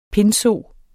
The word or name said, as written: pindso